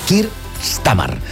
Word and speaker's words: Keir Starmer: Keir Starmer, que li sembla que no sàpiga pronunciar jo aquestes hores del matí. Keir Starmer.